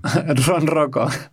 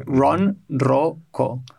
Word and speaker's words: ronroco: Ronoco. – Ronroco. – Trondoroco. Is that better? Trondoroco. – No, it's not Tron. It's kind of like Ron Howard, like Ron Roco.